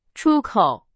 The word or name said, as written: 出口